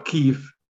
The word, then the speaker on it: Kyiv: Instead, it is K-Y-I-V. And I noticed the pronunciation has not changed, but many of the news people call Kyiv. – Right. – And it is Kyiv.